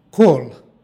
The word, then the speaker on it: kol: stihu u prvoj reči pročitali smo kol. Ovo je prilog i izgovara se sa L na kraju reči, dakle kol.